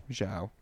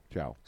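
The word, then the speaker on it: João: You know the Portuguese J-O-A-O? – J-O. – And there's like a squiggly over the A. – Yeah, I think it's Zhao. – Zhao. – Zhao. – Super Zhao.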